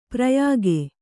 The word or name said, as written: ಪ್ರಯೋಗ